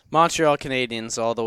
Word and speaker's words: Canadiens: Canadiens. It's an E, sports fans. It's a French-Canadian franchise.